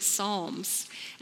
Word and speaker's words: Psalms: It starts with a P, but you pronounce it Psalms.